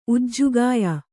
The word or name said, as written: ಉಜ್ಜುಗಾಯ